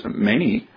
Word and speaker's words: Mani: because Mani, as I've heard it pronounced more regularly now by scholars,